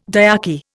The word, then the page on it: diarchy: Indian